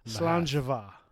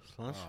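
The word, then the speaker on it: Sláinte mhath: But then the second word is M-H-A-T-H. M-H-A-T-H. Mahath, right? – Mahath. – But it's pronounced Slanjava. – Slanjava. – Oh, yeah. – So apparently M-H-A-T-H is pronounced Jva.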